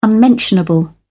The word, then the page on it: unmentionable: British English